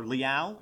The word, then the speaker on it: Leal: DeMarvin Leal, defensive tackle, or Leal, I believe is how he pronounces it, defensive tackle at Texas A&M.